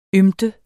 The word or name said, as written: ymte